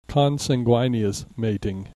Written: consanguineous mating

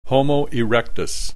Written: Homo erectus

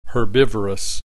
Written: herbivorous

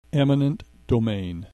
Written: eminent domain

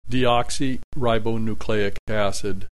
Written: deoxyribonucleic acid

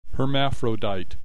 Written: hermaphrodite

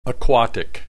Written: aquatic

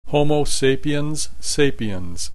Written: Homo sapiens sapiens

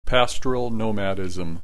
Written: pastoral nomadism

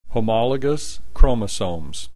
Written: homologous chromosomes

